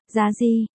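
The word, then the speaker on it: Giá Di (妲己): Một, Đát Kỷ đọc là, Giá Di, Bao Tự đọc là, Bây Sì, Tô Hy đọc là, Su Si, nhưng âm, Sì, trong, Bao Tự, đọc hơi khác một chút.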